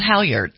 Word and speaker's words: halyard: It's called halyard.